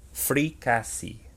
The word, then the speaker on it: fricassee: Fricassee. – Fricassee. Okay. Fricassee.